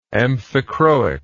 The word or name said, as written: amphichroic